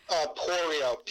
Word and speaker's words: Porio: Porio, P-O-R-I-O.